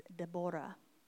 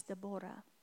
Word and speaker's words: Deborah: Actually, the way you're supposed to say it is Deborah. That's how you say her name. It's Deborah.